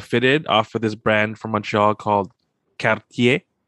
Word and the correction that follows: Quartier: think I said that wrong. – I think it's quartier. – Quartier. – Quartier. – Yeah, so it's spelled like quarter. – Quarter. – Yeah, basically with an I instead, quartier. – I instead of just the E.